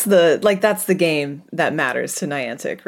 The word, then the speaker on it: Niantic: I say Niantic, but yeah.